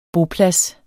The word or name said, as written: boplads